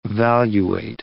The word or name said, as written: valuate